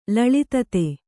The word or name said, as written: ಲಳಿತತೆ